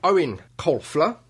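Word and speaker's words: Eoin Colfer: Ion Colfler. – Owen. – Oh, sorry. Owen Colfler.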